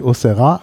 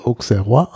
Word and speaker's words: Auxerrois: Was eigentlich Auxerrois ausgesprochen werden müsste, wird aber französisch trotzdem fälschlicherweise Auxerrois ausgesprochen.